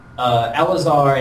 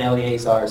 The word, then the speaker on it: Eleazar: Well, there's some contention about Eleazar. I've heard Eleazar and Eleazar.